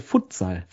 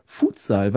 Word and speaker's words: Futsal: Für mich war der Begriff Futsal, das war sekundär für mich, und deswegen sage ich seit jeher Futsal, weil so, wenn es nämlich ein Kürzel ist, ein für Football Sala oder Football de Salao, dann müsste es auch Futsal ausgesprochen werden und nicht Futsal. Dieses Futsal ist so eine eingedeutschte Variante, finde ich.